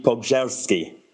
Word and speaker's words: Pogorzelski: Well, oddly, a Polish concierge this weekend told me that I've been saying it wrong for my entire life. So it's apparently Pogorzelski.